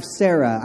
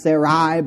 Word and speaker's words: Sarai: He took his wife Sarah. I know it's pronounced Sarai, but I don't feel comfortable with that, so I just say Sarah.